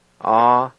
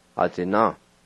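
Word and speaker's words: atsina: A-zi-na. A-zi-na.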